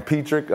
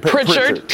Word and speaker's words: Pritchard: Petrick, am I saying it right? – Hey, Pritchard? – Pritchard.